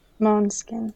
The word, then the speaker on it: Måneskin: It's Danish, means moonlight. Måneskin.